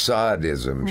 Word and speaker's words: sadism: Combined with a good dose of sadism. – Right. – Which should be pronounced sadism, shouldn't it? Because it's the Marquis de Sade.